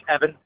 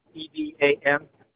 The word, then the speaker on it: Evan: Yes, Evan. EVAN. – Can you spell that, sir? I'm sorry. – EVAN.